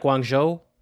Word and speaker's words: Guangzhou: government's sensors in Chengdu, Shanghai, Guangzhou, is how you say that, Guangzhou, and and Beijing, measuring levels of PM2.5, a particulate matter size of air pollutant.